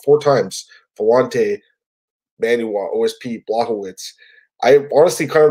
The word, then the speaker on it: Błachowicz: Błachowicz. He's a title contender. I got to pronounce it properly.